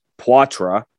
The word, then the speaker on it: Poitras: Although we found out, by the way, it is pronounced differently according to the Guelph Storm. Poitras.